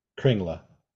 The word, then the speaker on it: Kringla: Kringla. – Kringle? – Kringla. – Kringla. It's spelled Kringle. – It's a K-R-N-G-L-E.